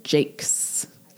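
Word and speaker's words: Jacques: This week, Moss Flower by Brian Jakes. I finally pronounced his name correctly. – Jakes. I'll remember that.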